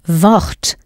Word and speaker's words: Wort: Wort. Note that W in German is pronounced like an English V, as in Volkswagen.